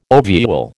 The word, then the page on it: ovule: American English